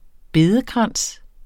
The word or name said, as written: bedekrans